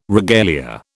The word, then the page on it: regalia: British English